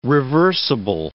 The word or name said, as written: reversible